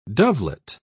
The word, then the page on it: dovelet: British English